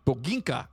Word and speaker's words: Boginka: Also known as the Mamuna or the Bobinka. – Nope, you said that wrong. – Boginka. – Yeah, there you go.